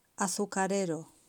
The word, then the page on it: azucarero: Spain